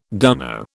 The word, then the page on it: dunno: British English